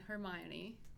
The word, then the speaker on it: Hermione: So the first topic for today is related to Harry Potter and Hermione.